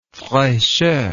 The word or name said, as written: frechet